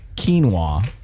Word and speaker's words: quinoa: It's quinoa.